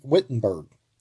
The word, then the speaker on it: Wittenberg: Wittenberg. I always pronounce it the German way, I guess.